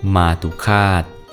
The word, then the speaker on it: มาตุฆาต: สวัสดีครับพบกับรายการคำวัดวันนี้ขอเสนอคำว่ามาตุคาตมาตุคาตสะกดด้วยมอม้าสละอาต่อเต่าสละอุขอละคังสละอาต่อเต่าอ่านว่ามาตุคาต